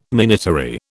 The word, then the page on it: minatory: British English